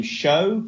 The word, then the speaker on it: Sjow: Greenship, I've seen a deck that's come out that seems to be used a lot, that, I think you pronounce his name Show, but it's like S-J-O-W.